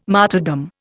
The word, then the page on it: martyrdom: Indian